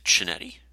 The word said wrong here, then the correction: Chionetti: it's pronounced Chionetti. – Chionetti. – Yeah, the C and H make a hard K sound.